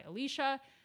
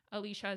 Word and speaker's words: Alicia: So if you're not following me there, my Instagram name is at Fitness by Alicia. Alicia is spelled A-L-I-C-I-A.